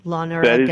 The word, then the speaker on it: Loneragan: And it's L-O-N-E-R-A-G-A-N, correct? – Lonergan. That is correct. – Yeah, because usually it's Lonergan, I think most people think, and certainly I pronounced it fast enough so it would sound like that.